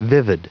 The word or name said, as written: vivid